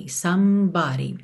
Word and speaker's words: somebody: Somebody também é importante perceber que esse E do meio não é pronunciado, somebody.